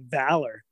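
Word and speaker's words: Vallar: I pronounce it valor. Like valor, I don't know if okay.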